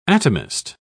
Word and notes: atomist: British English

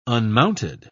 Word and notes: unmounted: British English

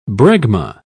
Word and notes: bregma: British English

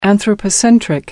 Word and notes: anthropocentric: American English

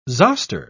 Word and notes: zoster: British English

